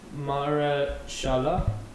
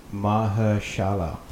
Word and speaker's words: Mahershala: But his first name's like Mahershala. – Mahershala. – Mahershala. – Mahershala, I think. – Yeah, Mahershala.